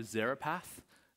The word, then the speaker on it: Zarephath: He's going outside of Israel up to Zarephath, is how you say that. I said it right that time.